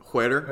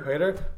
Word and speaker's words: Huerter: Huerter. – Huerter.